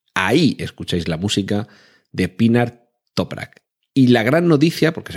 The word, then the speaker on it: Pinar Toprak: Se trata de la compositora Pinar Top, mm, a ver que lo pronuncie bien, Pinar Toprak, ¿Cómo suena, como Pinar, donde hay muchos pinos, Pinar Toprak, sin H's ni cosas raras.